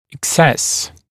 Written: excess

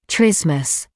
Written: trismus

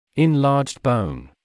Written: enlarged bone

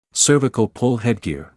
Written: cervical pull headgear

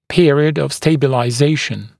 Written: period of stabilization

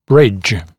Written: bridge

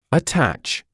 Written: attach

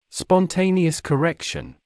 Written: spontaneous correction

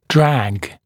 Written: drag

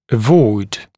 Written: avoid

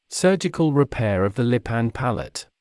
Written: surgical repair of the lip and palate

